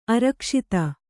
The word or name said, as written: ಅರಕ್ಷಿತ